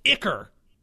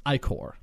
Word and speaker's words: ichor: The hound is covered in a toxic ichor. Some people think it's pronounced ichor, but they're wrong. I'm here to tell them today, you are wrong.